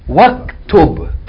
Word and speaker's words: waktub: What if you had ikra, read, and write? How would you pronounce this? This is hamzatul wasl. How would you pronounce it? Waktub. Iqra, waktub. Why is it waktub? Because this hamza tawassul. You have to join whatever is before to whatever is after it.